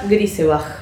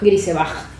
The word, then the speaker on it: Grisebach: Y ahora vamos a pasar a Western, la película alemana de Valeska Gierber, estoy pronunciando mal el apellido. La vieron 1500 personas. Hoy estoy muy con la cifra al pedo, ¿no? Pero para ver como la diferencia. – Valeska Grisebach. – Grisebach.